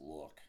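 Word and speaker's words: lewk: Except I'm sure it's pronounced look.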